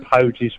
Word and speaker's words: Poges: I'm glad you've pronounced it Poges right again, yes.